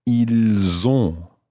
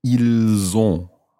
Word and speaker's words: ils ont: So that's I-L-S. And the verb itself is O-N-T. So what you end up with is pronouncing the S of the il in that one because it's following, or it's followed by a vowel. Ils ont. – Ils ont. – And in that situation, the S of il sounds more like a Z. Z, as opposed to S. Z. Ils ont.